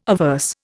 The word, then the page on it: averse: Indian